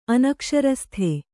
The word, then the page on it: ಅನಕ್ಷರಸ್ಥ: anakṣarastha